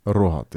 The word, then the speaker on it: Rohat: Rohat, yeah.